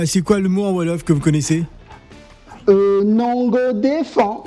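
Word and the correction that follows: Nanga def: Ah non, non, c'est Nangadef.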